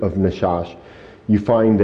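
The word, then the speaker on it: nachash: And so the Hebrew word for serpent in this particular passage, as I looked it up, was Nashash. So I always have trouble with these words pronouncing sometimes, but it's Nashash.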